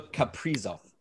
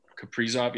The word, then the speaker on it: Kaprizov: Kaprizov? – Kirill Kaprizov. – Kaprizov, yeah.